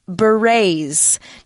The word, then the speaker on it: berets: Now, the article says this word, berets. Now, this one's pronounced a little bit differently than you might think because it's a French word. – Oh, okay, 所以第一个我们帮大家选的就是 Wow, 贝雷帽。那但是它的英文的拼法 B-E-R-E-T-S, 但是大家可能觉得，诶，为什么那个 T 跟 S, howcome they are silent because, yeah, 因为它可能是源自于法文，所以它的发音有点不同哦。